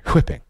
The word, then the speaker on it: whipping: And he pronounces whipping. I like the H in there. Whipping.